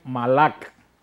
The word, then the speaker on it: malak: Y se pronuncia en hebreo malak.